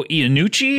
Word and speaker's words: Iannucci: Created by Armando Iannucci.